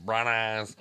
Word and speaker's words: Branagh: But but just the fact that we go from Melanie Griffith to Kenneth Branagh's, I can't say his name.